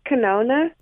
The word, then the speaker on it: Kanone: Well, it's pronounced canona. – Okay. – I did get it wrong. – Okay, and so don't feel badly. I can't even pronounce it. So how do you spell canona? Now, because I'm guessing you've learned it since. – K-A-N-O-N-E.